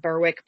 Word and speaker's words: Berwick: I know it's spelled Berwick, but I have looked it up so that we don't get, you know, utterly murdered in the comments, and it is Berwick, according to the Wikipedia page anyway.